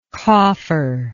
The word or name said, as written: cofferlike